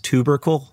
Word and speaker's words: tubercle: I think it's technically pronounced tubercle, but I don't like pronouncing it that way. It seems wrong.